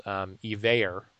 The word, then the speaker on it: Evaer: So we found this software called Evayer, which is spelled EVAER.